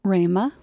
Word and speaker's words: Rhema: Is a graduate of, I'm going to say it out loud, R-H-E-M-A. Do you pronounce that acronym REMA? – REMA.